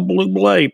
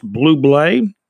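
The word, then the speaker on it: Bublé: And Michael Blue Blay, pronounced Blue Blay.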